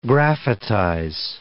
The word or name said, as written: graphitize